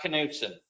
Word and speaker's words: Knudsen: My name is Scott Knutson. – Okay. – And spell that last one for me. – K-N-U-D-S-E-N.